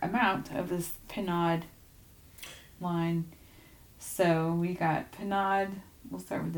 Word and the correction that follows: Pinaud: For the record, it is Pinaud. – Oh, this is Pinaud. Okay. – Pinaud. – I think the accent on the second syllable there.